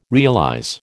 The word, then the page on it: realize: American English